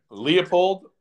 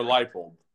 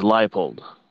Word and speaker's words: Leipold: Their coach, though, Lance, and now I always butcher it, Leopold or Leipold? – I think it's Leipold.